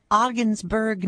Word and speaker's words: Ogdensburg: We have got, it's Pete calling from Ogdensburg, New York. Did I pronounce that right, Pete? – Yeah, that's pretty close.